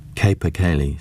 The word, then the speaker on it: capercaillie: Eki paused, unable to pronounce the word. "Capercaillie," said Warwick.